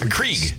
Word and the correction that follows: Keurig: Keurig. – Yeah. Keurig. Keurig. Yeah. You don't even know how to say it.